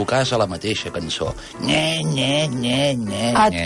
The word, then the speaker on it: Gne Gne: Té una cançó que es diu Nye-Nye, que en italià s'escriu G-N-E, G-N-E. Es pronuncia Nye-Nye.